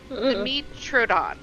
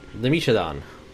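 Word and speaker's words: Dimetrodon: Dimitrodon. – Dimitrodon.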